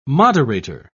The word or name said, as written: moderator